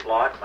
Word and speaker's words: Block: This is Richard Block. My last name is spelled B-L-O-C-K.